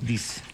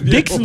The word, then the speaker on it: disc: Hoy, un día como hoy, Sony lanzaba su primer lector de Compact Discs. – ¿De qué? – Del Compact Disc. – Disc. – Dix. En Paraguay no, no, espera. Dix no es. D-I-S-C. Claro, disc.